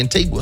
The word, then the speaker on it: Antigua: For the longest time, I didn't know how to pronounce Antigua. And I'm still not certain if it's Antigua or Antigua. I just watch YouTube videos and have heard more people say Antigua, so I'm going with that.